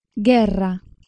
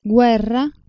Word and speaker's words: guerra: Mentre in italiano nelle sequenze gue e gui la vocale u si pronuncia, in spagnolo nelle stesse sequenze non è una vera vocale, ma fa parte insieme alla consonante g del grafema del suono velare occlusivo sonoro ghe. Guerra, guerra, anguilla, anguilla.